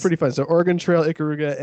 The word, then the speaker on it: Ikaruga: Number two, you're going to probably correct me on this, but I heard them talking about this game on their podcast, Ikaruga.